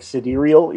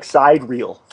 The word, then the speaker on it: sidereal: As a matter of fact, the term sidereal, side reel, like sidereal is how you pronounce it, but it looks like side reel.